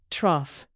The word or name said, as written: trough